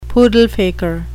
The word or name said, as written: poodle-faker